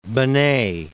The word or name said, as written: Benet